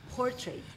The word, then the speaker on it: portrait: Ahí la A es casi inexistente cuando uno lo pronuncia, es portrait. Oh my God. – Mm. – Es casi con una, es con una, I, lo que se llama una I eh, corta, creo que se llama eso, portrait.